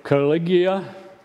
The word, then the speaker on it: Caligula: Caligula. Caligula, almost right.